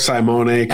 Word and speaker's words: Saimone: Am I pronouncing, is it Simon or Saimone? Do we know how to pronounce his name correctly from Nolan Catholic? – I go with the latter. I go with Saimone.